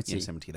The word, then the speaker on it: Yosemite: So the new version is going to be called, how do you pronounce it? Yosemite? – Yosemite, that's it.